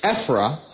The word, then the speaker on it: Ophrah: And when you look up Ophrah, I feel pronouncing it right, Ophrah, how do you pronounce it? They pronounce the O with an E. – Okay. – Ephrah of Benjamin.